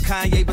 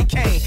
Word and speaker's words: Kanye: Now all I need is y'all to pronounce my name. It's Kanye, but some of my plans they still say Kane.